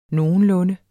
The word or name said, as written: nogenlunde